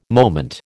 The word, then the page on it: moment: American English